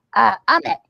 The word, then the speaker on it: Amit: Is it Amit? – Oh, Amit, yeah. – Amit? How do you spell it? A-M-I-T? – A-M-I-T, yeah.